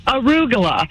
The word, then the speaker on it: arugula: Arugula is like Caligula.